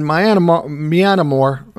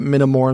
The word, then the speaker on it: Myanmar: And somebody says, why can't what happened in Myanmar, I don't know, I mispronounced it. I called it Minamore, and somebody says, I said it wrong.